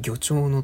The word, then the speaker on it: 魚鳥: え光る魚鳥のっていうのはあの魚と鳥って書いて魚鳥って読ませてる